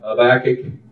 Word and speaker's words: Habakkuk: Habakkuk. Is that how you pronounce it? – Habakkuk. – Sounds good.